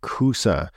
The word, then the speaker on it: kusa: In Egypt, she writes, the word for zucchini is kosa, but when she moved to Lebanon, the word was pronounced differently, something more like kusa.